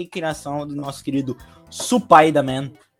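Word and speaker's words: Supai da Man (スパイダーマン): Lembrando, gente, Supai da Man é porque em Spider-Man, em japonês, a pronúncia é Supai da Man.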